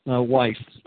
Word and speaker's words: Weiss: Weiss.